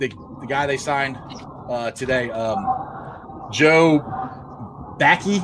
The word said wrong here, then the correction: Bachie: Bachie. I think that's how you say his name. Uh, it's, going to be depth. It's going to be uh, Bachie. know, I think it's stuff Bachie. like that. – Bachie, yeah. So, but I think like last year, the yeah.